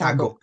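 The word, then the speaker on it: sagok (사극): yung sagyuk ba tawag nun? Pronounce sagyuk? – Yes, sagok. Sagok.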